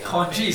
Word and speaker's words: congee: Is it congee? Is that how you say it? – Congee, yes.